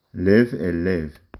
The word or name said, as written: לב